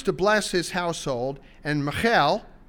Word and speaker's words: Michal: we're going to pronounce that very Hebraically rather than Englishly, because some people don't like a girl named Michael. It's really not a problem with me, but we'll say Michal,